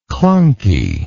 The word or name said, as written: clunkiness